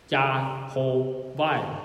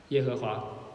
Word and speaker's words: 耶和华: 就是将 Adonai，你知道三个元音 ROI，这三个元音放在这三个辅音上面， Yahovai 耶和华， Yahovai 耶和华，明白吗？其实根本没人知道这个字怎么读，耶和华只是我们想象的读法。我们将 Adonai 另外一个词的三个元音放在这个辅音里面，然后把它拼成一个新的读法，非常的就是脑洞大开的一个做法，所以读成耶和华。